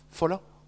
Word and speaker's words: Fowler: C'est James Fowler, F-O-W-L-E-R. Comment ça se prononce ? Fowler ? Fowler. Il faut avoir une patte à la bouche.